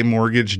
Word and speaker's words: mortgage: And as long as we're talking about people getting upset, I want you all to just remember there's a T in mortgage. And when you say mortgage, do your best to not pronounce the T.